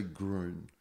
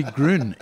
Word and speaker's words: Gruen: it's actually Gruen, like one syllable.